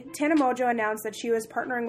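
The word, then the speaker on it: Tana Mongeau: Sitting here with Tana Mojo. I said her name right this time, I think. – I'm proud of you.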